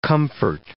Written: comfort

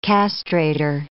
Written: castrator

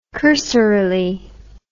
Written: cursorily

